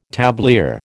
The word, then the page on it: tablier: American English